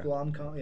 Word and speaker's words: Blomkamp: Blomkamp, yeah.